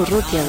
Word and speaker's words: Urrutia: Jaime Ruti, si es que, si es que unas cuantas voces conocidas. Jaime Urrutia, lo dije bien, no sé qué dije.